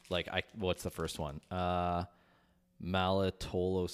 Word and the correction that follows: maltitol: syrup, maltitol.